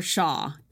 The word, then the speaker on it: Shaw: It's Tiana underscore Shaw. S-H-A-W. And my first name is spelled T-H-E-A-N-A.